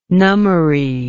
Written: nummary

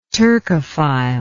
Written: turcophile

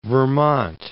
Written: Vermont

